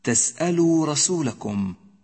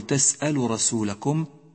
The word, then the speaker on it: تسألوا: أم تريدون أن تسألوا رسولكم، تسألوا رسولكم، لا تقل تسأل رسولكم، لأنك بذلك تكون قد أهملت حرف الواو من النطق.